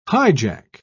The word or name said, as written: hijack